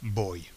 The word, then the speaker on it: voy: But of course, in Spanish, as you know, the pronunciation is no different between a B and a V. So, in standard Castilian Spanish. – Yeah. So, boy, V O Y.